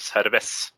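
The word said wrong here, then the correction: Servette: Vad var det? Ja. – Jag säger servett, men... – Servett, servett, servett. Ja.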